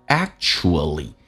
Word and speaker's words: actually: How bad， bad 跟 it 有一个零叫 badly， it 跟 actually，首先我们讲叫 actually 这个单词啊，标准音标叫做 actually，首先 true 发成这个发音，而且 ch 可以发成 sh 这个发音，而且 act 的 k 是不发音，就变成 actually。